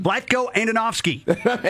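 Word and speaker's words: Vlatko Andonovski: Vlatko Andonovsky.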